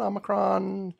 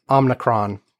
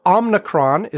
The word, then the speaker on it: Omicron: It's Omicron or Omicron. Uh, and those are apparently both legitimate pronunciations, depending on accents and where you live, blah, blah, blah. But uh, Omnicron is wrong. Unicron is wrong.